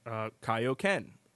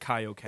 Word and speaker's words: Kaioken: but Kaio should be Kaioken. – Right, yes. That's, and they fixed, well, you know, in the Ocean dubs of the first three movies, they got that pronunciation right. – Well, there were three voice actors of Goku in the U.S., and one of them got it correct. They said Kaioken.